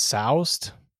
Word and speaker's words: soused: Yeah, but it's soused, and I was like, when I first heard that, I was like, what the shit? And then they're like, no, that's how you say it.